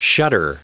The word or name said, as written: shudder